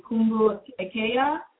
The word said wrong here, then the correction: Kumbu Lekaya: Kumbu Lekaya. – Kumbu Lekaya. Okay, excuse me.